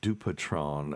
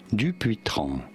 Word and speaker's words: Dupuytren: so I have a thing that's not dissimilar from this, and it's uh, called Dupitron uh, contracture.